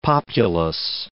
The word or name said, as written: populace